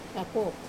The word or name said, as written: papo